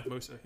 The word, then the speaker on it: Musa: King Mansa Musa, or however Musa. – I say his name. – Yeah, Musa.